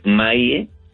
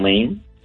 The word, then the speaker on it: Maie: Esta organización tiene sus oficinas en el 7730 de la calle Maie. – ¿Cómo se escribe? – M-A-I-E, Maie, no Main.